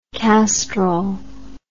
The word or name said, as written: Castrol